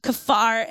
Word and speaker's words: kapar: It's actually spelled K-A-P-A-R, but it's pronounced kafar, and it's translated in English to mean a lot of different things.